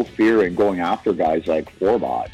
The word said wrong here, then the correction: Forbort: So, I mean, I still don't even know how to say the guy's name. Forbot, Robot, whatever the hell his name is. – Winnipeg Jets defenseman, Derek Forbort.